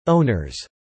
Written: owners

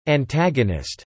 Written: antagonist

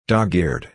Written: dog-eared

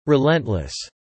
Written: relentless

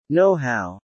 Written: know-how

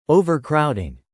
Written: overcrowding

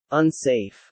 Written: unsafe

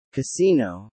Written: casino

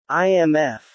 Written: IMF

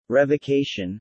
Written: revocation

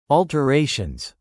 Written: alterations

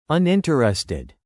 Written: uninterested

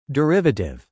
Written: derivative

